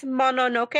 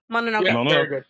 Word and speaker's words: Mononoke: So Princess Mononoke, am I saying it right? – Yeah. – Did I say it wrong? – Yes. – Mononoke.